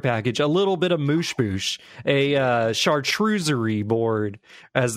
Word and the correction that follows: charcuterie: It's a charcuterie board. – That's charcuterie board.